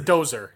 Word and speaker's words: dozer: It's a dozer. You sound like a damned idiot when you say it wrong.